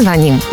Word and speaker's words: звоним: Ударение всегда ставим в конце слова во всех формах. Ты звонишь, он звонит, ну и, наконец, мы звоним.